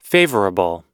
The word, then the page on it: favourable: American English